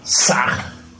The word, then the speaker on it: SAH: Más al exhalar, se podrá articular la sílaba SAG en forma sonora. HAM escribe con las letras H-A-M. SAG se escribe con las letras S-A-H. La H suena siempre como J.